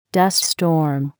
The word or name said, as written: dust storm